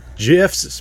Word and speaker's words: GIF: Yeah, damn GIFs, pronounced with a J.